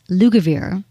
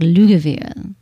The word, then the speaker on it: Lugavere: And um, his name is Max Lugavere, but I want to say it like Lugavere. Lugavere. Lugavere.